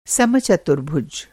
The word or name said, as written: समचतुर्भुज